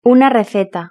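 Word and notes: una receta: Spanish (Spain)

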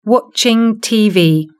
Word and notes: watching tv: British English

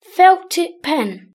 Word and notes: felttip pen: British English

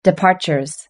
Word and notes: departures: British English

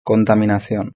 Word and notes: la contaminacion: Spain Spanish

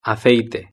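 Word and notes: aceite: Spanish (Spain)

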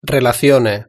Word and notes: relacione: Spain Spanish

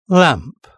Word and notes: lamp: British English

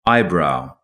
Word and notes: eyebrow: British English

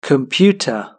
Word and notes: computer: British English

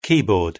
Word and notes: keyboard: British English

